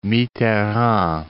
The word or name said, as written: Mitterrand